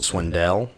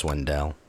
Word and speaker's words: Swindell: Swindell. – Swindle. Swindell. Whatever. – I'm pretty sure it's pronounced Swindell.